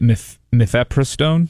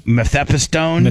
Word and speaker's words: mifepristone: I would say it mifepristone. – Mifepristone? – Mifepristone.